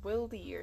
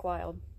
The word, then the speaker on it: Wilde: I thought it was like Wildy or something. – No, Nick Wilde.